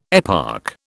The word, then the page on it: eparch: British English